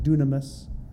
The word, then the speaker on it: dunamis: The Greek word for power is pronounced dunamis. And it's from where we get dynamite.